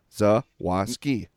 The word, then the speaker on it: Zawoski: It looks just like it sounds. Zawoski.